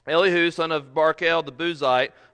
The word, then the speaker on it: Elihu: I've heard it pronounced Elihu and Elihu. I'm going to go with Elihu.